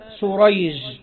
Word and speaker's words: سريج: قال: الأول بالشين المعجمة والحاء المهملة اللي هو شريح، وهو تابعي يروي عن علي رضي الله عنه. والثاني بالسين المهملة والجيم اللي هو سريج، وهو من شيوخ البخاري.